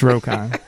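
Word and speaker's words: Roquan: I don't care how anybody pronounces it. It's Rokan. Because is it R A Q U A N? – It's R O Q U A N.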